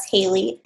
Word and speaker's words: Halie: That's Haley, and it's spelled Halie.